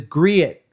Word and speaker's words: griot: And I was also listening to some of your interviews, and you talk about the griot. How do you say that word? – Griot is how I've always heard it.